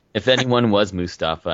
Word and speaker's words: Mustafa: The other thing we wanted to talk about is just, I'm sure you've all heard about the Old Spice guy, Isaiah Mustafa. Is that how you pronounce his last name, Mustafa? – I believe so, yeah.